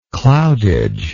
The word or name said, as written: cloudage